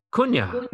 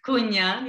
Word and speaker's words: Cunha: Our guest today, Anita, how do you say your last name? Kunna? – Cunha. Cunha, yeah, Cunha. it's a Portuguese.